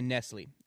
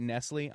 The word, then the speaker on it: Nestlé: So this is a duel between Madame de Polignac versus the Marquis de Nestlé. And when I say Nestlé, I'm going to pronounce it like the chocolate drink makers.